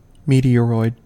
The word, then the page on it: meteoroid: American English